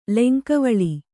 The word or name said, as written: ಲೆಂಕವಳಿ